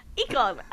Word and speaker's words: Ocon: I nearly said his name wrong as well. I started it with an E. I went, Ocon. – Ocon.